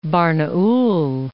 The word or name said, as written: Barnaul